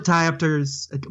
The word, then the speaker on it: diopters: Split diopters. Why does that sound so weird? – You're saying it right. – You're doing it right, yeah.